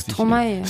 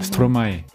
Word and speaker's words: Stromae: Wie spricht man den eigentlich aus? – Stromae. – Stromae. Stromae. Okay.